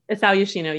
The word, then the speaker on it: Isao Yoshino: Is that how I pronounce his name? – Isao Yoshino, yes.